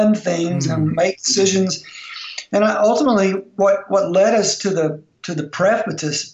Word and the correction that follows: precipice: how do you say that word? – Precipice.